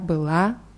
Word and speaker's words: была: Bei была ist die Betonung auf a. Вчера была на работе.